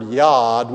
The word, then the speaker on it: yad: which is the word yad, we spell it Y-A-D in the English language, is used no less than eight times.